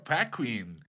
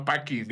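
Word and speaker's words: Anna Paquin: Dice Badía que se pronuncia Paquin. No, Packing.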